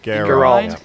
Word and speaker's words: Geralt: But to be Geralt, is it? Am I saying that right? Geralt. Is that right? – Geralt.